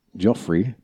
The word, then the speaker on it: Giuffre: Giuffre, said in an NBC interview that Prince Andrew was pouring with perspiration when they danced at a London club called Tramp.